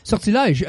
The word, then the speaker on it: Sortilège: S-O-R-T-I-L-E-G-E. – I think there's an accent. – Sortilège. Yeah. Got it. Okay. – There's an accent on the first E. I think it's sortilège. – Either sortilège. – Lège. – But I pronounce it wrong because I'm from Northern Quebec, so I say sortilège.